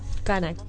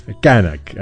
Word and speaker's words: Knak: Kanak. – Kanak. Bueno, yo me tenés que ayudar con las pronunciaciones, porque para mí... Kanak, ahí va. En realidad se escribe K-N-A-K, sí.